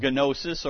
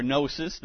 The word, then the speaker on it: gnosis: The heresy gets its name from the Greek word gnosis or gnosis, depending on your pronunciation.